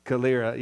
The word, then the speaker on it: Calera: But, you know, just to come and be a part of what God's doing here in Calera. Is that how you pronounce it? Am I saying it right? Okay. No. Calera. Yeah, I heard you say that. And I was like, great. Now I've heard the correct way of saying that.